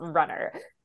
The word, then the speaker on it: rnnr: That was my first question, was how to pronounce it, because it is R-N-N-R. It's not spelled, yes. Runner.